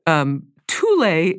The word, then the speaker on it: Thule: And then they're competing with the Thule is the correct pronunciation of they're not an indigenous people. There are people who are indigenous to Alaska who crossed across North America, the northern edge of North America, so across northern Canada, and then from there settled, moved to Greenland. And I was going to say Thule is spelled Thule, and I think most Americans would say Thule.